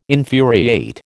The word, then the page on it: infuriate: American English